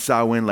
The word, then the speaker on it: Samhain: That's not pronounced, you know, you might pronounce it Samhain or something like that, but it's pronounced Samhain, like cow in Samhain.